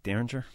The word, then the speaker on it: Derringer: Derringer? – Yeah.